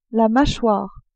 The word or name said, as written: machoire